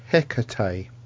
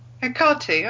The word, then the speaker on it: Hecate: Laurie Nick says, by the way, it's pronounced Hecate. – Oh, yes. – Hecate. Okay. – Oh, Hecate.